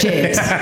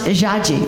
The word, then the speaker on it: Jade: My real name is Jade. In Portuguese, it's pronounced Jaji, which actually means penis in Chinese.